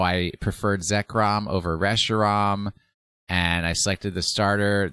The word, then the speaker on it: Reshiram: Reshiram. – Reshiram. – No, it has to rhyme with Zekrom and Zekrom Kiram. and Reshiram. Zekram.